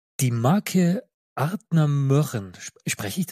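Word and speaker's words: Ardnamurchan: Ja, es gibt also dieses ganz korrekte, würde Adnamurchan heißen, Adnamurchan. Also dieses Ö-Klingende, das kann man also ein bisschen als Deutscher bringen und weil für uns Deutsche ist es eigentlich nicht richtig aussprechbar.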